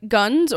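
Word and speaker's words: Gunness: This is Belle, and I've heard it pronounced like Guns or Ganesse. So I'm going to call her Ganesse.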